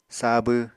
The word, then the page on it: sabw: Apatani